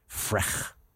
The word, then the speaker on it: Frech: Frech is how you pronounce that.